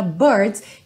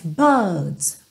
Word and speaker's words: birds: como aqui na palavra birds, e eu falei agora com uma pronúncia bem americana, birds, que no britânico vai ser mais buds.